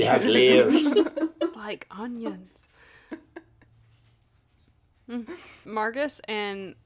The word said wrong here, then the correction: Marcus: did you, did you say did you say Margus? Marcus.